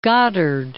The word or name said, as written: Goddard